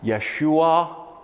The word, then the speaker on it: Yeshua: Yeshua means salvation. It's pronounced Yeshua.